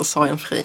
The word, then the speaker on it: Sorgenfri: Får man påpeka ett uttal? – Nej. – Det heter Sorgenfri. Jag lovar.